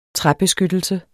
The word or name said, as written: træbeskyttelse